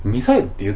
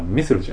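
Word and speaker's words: missile: ミサイルって言ってるんですよ。あのミサイルって日本の発音ですよね。あの英語やったらミスルじゃないですか。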